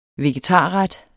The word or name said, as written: vegetarret